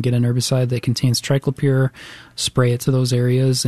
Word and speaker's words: triclopyr: And so what I'll recommend for this is a conventional herbicide that contains triclopyr. And I'm going to spell that for you. T-R-I-C-L-O-P-Y-R.